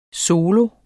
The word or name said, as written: solo